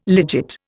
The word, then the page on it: legit: Indian